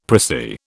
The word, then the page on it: pricy: British English